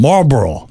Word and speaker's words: Marlboro: Marlboro. – Am I saying it right? – Yeah.